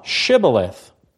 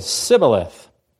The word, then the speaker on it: Shibboleth: And if he said, 'No,' then they'd say to him, 'Say now, Shibboleth.' But he said, 'Sibboleth,' for he could not pronounce it correctly.